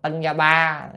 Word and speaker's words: Tân Gia Ba: Đó, rồi mấy cái chữ như Singapore đó đọc là Tân Gia Ba,